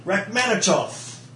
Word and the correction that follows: Rachmaninoff: And you still got it wrong. – It is Russian or something. – He is. Rachmaninoff. – Rachmaninoff. – There you go.